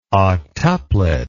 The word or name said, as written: octuplet